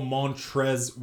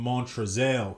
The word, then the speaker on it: Montrezl: Montrez Harrell. I figured out that's how you say his name, but it's spelt Montrez with an L on the end, like Montrezl. I used to call him Montrezl because that's how black people say Daryl is Darrell.